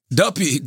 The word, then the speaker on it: Duppy: How do you pronounce it? – Kanye. – Duffy? – Duppy. Duppy.